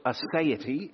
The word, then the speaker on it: aseity: It's pronounced aseity, if you've never seen it before.